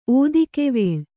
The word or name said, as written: ಓದಿಕೆವೇಳ್